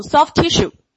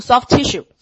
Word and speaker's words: soft tissue: 34 题你可以听到非常清楚的 soft tissue，直接连读成 soft tissue，因为两个 t，对吧？它有一个就失去发音啊，直接读 soft tissue。